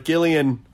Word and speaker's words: Gillian: This episode is Gillian Jacobs, who, by the way, Will Wheaton, it pronounces the G the correct way, hard G. No shade to any soft G Gillians out there, who like my sister-in-law, who's a Gillian. But Gillian, hard G, just want to drive it home for the Wheaton, all right? Hard G Gillian, soft J Jacobs.